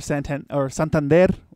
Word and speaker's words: Santander: Or Santander, I guess, would be the actual way to say it. – Oh, wow. You said it right. – That's because it's Spanish.